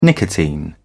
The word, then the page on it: nicotine: British English